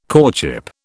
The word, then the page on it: courtship: British English